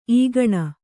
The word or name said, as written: ಈಗಿನ